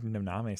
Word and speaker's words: Noname: This is Noname. Actually, it's No Name. What am I saying? The artist's name is No Name.